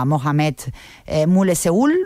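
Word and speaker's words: Mohammed Moulessehoul: Yasmina Jadra, como sabéis, es el seudónimo femenino del escritor argelino en lengua francesa Mohamed Moulesseoul.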